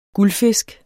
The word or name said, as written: GULDFISKEN